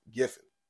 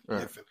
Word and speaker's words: Giffen: And then we lost Keith Griff. I wasn't going to say the man's name wrong. Keith Giffen. When you see his name, you want to say Griffin for whatever reason, but it's Keith Giffen.